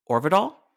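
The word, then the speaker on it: Øvredal: But Andre, how do you say his last name? – Orvidal.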